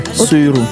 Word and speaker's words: Suyru: Suiru. – Suiru. – Oui. – J'ai bien prononcé. – S-U-Y-R-U. Suiru.